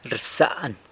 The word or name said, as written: rsan